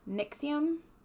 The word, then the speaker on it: NXIVM: It's NXIVM, and on, like, you know, the little side word, it said Nixium. – Okay, sure. – So it might be Nixium.